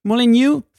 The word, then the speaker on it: Molineux: En dan ook nog daar in dat stadion van Wolves met die hele moeilijke naam Molineux. Zeg ik het goed? – Molineux.